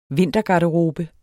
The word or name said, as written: vintergarderobe